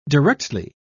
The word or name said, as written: directly